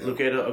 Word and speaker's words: Lucado: Is that how you pronounce his last name? – I think. – Is it Lucado, Lucado? I mean, I don't even know. – I've always heard Lucado. – Lucado, okay, cool.